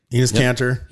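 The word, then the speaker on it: Enes Kanter: Well, so this, I just posted, it was on Breitbart, where, is it Enos Kanter? Is that how you say his name? – Yep. – Enos Kanter.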